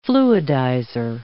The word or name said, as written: fluidizer